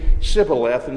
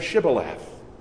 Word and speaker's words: Shibboleth: If instead of saying Shibboleth, the man wanting to cross would say Sibboleth, he was to be immediately incarcerated, because only those of Ephraim would say Sibboleth instead of Shibboleth.